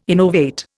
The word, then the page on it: innovate: Indian